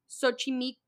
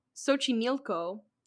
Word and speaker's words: Xochimilco: The Island of Dolls, or La Isla de Muñecas, is located in a borough of Mexico City called, I had to write this out phonetically, Xochimilco. Boop, boop, boop, boop. It's called Xochimilco, but it's spelled with an X in the front.